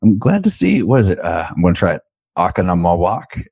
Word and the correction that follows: Oconomowoc: Okanamawak. – Oh, dude, I was way off.